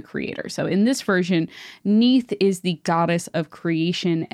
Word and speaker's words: Neith: So the only other like important goddess to note with Ra is Neith. It's very funny. It's spelled like Keith, but with an N instead of a K, oh, which I really like.